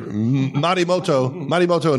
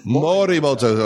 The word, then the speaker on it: Morimoto: Yeah, we were at Marimoto in Philadelphia. – Thank you, Marimoto. Did I pronounce it right? – Marimoto? – Morimoto.